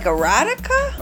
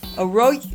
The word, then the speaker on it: Eroica: Like erotica? – Eroica. – Well, it sounds like erotica, so there. Okay. – Is this spelled wrong? Is it supposed to be erotica? – It's eroica.